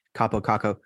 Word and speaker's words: Kaapo Kakko: Kaapo Kakko.